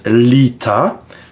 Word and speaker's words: Litha: Bon, Lita se prononce Lita, mais c'est assez facile à comprendre.